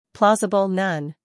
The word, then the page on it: plausible: ˈplɔːzəb(ə)l